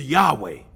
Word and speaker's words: Yahweh: And the way that this is pronounced is Yahweh.